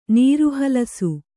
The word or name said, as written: ನೀರುಹಲಸು